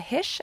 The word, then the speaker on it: Hische: They can find me at my first name, last name, which is Jessica Hische, and Hische is spelled H-I-S-C-H-E on Instagram or Twitter.